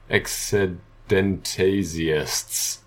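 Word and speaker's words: eccedentesiasts: You would call those people doing the pretending exidentias. Exidentasiasts. – Spell it for me. – E-C-C-E-D-E-N-T-E-S-I-A-S-T, and then S for the plural.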